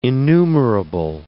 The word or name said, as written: innumerable